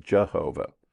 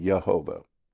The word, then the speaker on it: Yehovah: That's the reason why the King James said Jehovah. – Right. – They pronounce the J, we don't. – Right. – Yehovah. It's a three-syllable name.